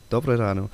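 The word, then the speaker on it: Dobré ráno: Is it Dobre Rano? – Yes, almost correct. It's Dobre Rano, yes. – Dobre Rano.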